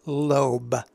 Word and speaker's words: lobe: So the verb that's used in the Hebrew text is the verb lobe.